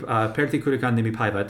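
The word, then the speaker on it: Pertti Kurikan Nimipäivät: So this group, Pertti Kurikan Nimi Paiva, did I say that right? – Very well, yes.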